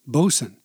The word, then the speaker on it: boatswain: Similarly, nautical names like boatswain, pronounced bosun, ketch, and pilot might or might not be applied to someone in the maritime trade.